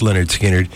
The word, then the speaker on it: Lynyrd Skynyrd: 46 years ago in 1973, gosh, this goes back to my college days, Lynyrd Skynyrd released their self-entitled album, Pronounced Lynyrd Skynyrd, featuring Gimme Three Steps, Simple Man, Freebird.